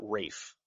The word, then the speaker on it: Ralph: Ralph Fiennes. – He pronounces it Rafe. So I don't care. It's R-A-L-P-H.